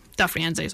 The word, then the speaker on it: Da Firenze: Yeah, Di Firenze. Da Firenze, sorry. Absolutely butchered that, I'm sorry.